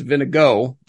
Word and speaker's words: Vingegaard: The guy's name looked like Vingegaard, but his name is Vinigo. That must be some Danish pronunciation thing.